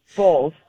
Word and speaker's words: Bolles: So the appropriate pronunciation of your last name, B-O-L-L-E-S, is what? – Bowles. – Bowles. Okay. Yes.